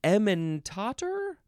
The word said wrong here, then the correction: Emmentaler: No, ementaler. I thought it was ementator for a second. Ementaler, capital E-M-M-E-N-T-A-L-E-R.